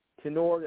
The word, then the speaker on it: Tenore: The spelling is, the last name is Tenore. That's T-E-N-O-R-E. The E is silent, which throws everybody off.